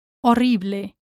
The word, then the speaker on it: horrible: Again, remember here that the H is silent, horrible. Try to pronounce the double R as best as you can, horrible. And definitely remember to pronounce the E, horrible. Don't drop that E, horrible.